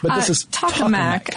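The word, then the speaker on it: tokamak: How did we decide that this is pronounced? – Tacomac. Yeah, We've got Tacomacs here in Atlanta, yeah, so it keeps throwing me off.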